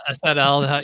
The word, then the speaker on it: acetaldehyde: Acetaldehyde or whatever. How do you say that? – Yeah, acetaldehyde.